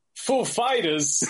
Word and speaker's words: Foo Fighters: Where he, Christopher Walken came up to him and asked him, and he's like, you know, your band name, like, which syllable is supposed to be accent? And he's like, oh, it's actually Fighters. It's the second. So, ladies and gentlemen, Foo Fighters.